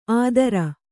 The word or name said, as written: ಆದರ